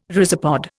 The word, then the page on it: rhizopod: Indian